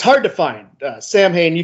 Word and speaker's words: Samhain: going from the Misfits to Samhain, which is, you know, Samhain, but, you know, he pronounces it Samhain like a goofball, so we'll just go with that.